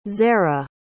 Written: zera

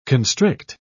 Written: constrict

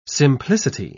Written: simplicity

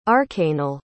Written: arcanal

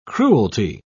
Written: cruelty

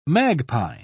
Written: magpie

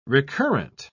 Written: recurrent

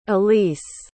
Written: alise

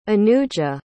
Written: anuja

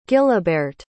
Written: gilibeirt

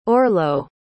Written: orlo

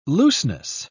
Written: looseness

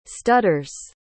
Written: stutters

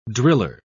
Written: driller

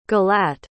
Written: gilat

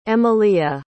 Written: emalia

